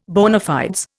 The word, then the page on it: bona fides: Indian